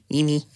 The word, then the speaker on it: Jimmy: Jag vet inte, hur uttalar man Jimmy Åkesson? – Jimmy.